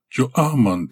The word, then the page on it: jo-aman: Galindri